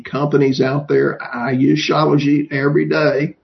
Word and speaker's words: Shilajit: Regarding minerals, is Shilajit a good supplement? S-H-I-L-A-J-I-T. – Yeah, I pronounce it Shilajit. – Okay, Shilajit, okay.